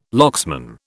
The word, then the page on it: locksman: British English